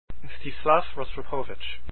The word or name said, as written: rostropovich